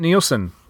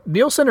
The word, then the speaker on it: Nielsen: So a little bit, we talked, you know, a little bit about the both the main characters, but Bridget Nielsen, is it Nielsen or Nelson? – I think it's Nielsen. That's how I've always heard.